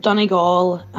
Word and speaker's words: Donegal: I need to correct you on your pronunciations. It's Sligo, Mayo, and Galway. Then Armagh, Tyrone, and Offaly. And then Leitrim, Donegal, and Laois.